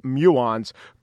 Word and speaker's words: muon: Muons, M-U-O-N, but pronounced muons, could be the answer.